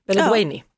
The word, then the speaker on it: Beledweyni: Du, imorgon så är det premiär för SVTs nya kriminaldrama då, Detektiven från Beledweyni. – Beledweyni. – Ja. Mm. Nu sa jag rätt.